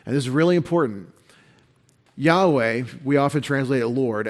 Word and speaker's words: Yahweh: We conjecture Yahweh, since there are no vowel points from the Masoretes on how to say this word. And so Yahweh is the Hebrew scholarship best answer, Yahweh or Yahweh, on the pronunciation of the sacred four-letter name of God presented in Exodus 3.